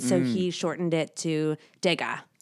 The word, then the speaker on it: Degas: Well, it's actually Degas. – En français, alors, c'est Degas. Mais en anglais, Degas, perhaps, right? – Well, initially, when he was born, his name was D-E space G-A-S, which means from Ga.